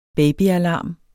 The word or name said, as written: babyalarm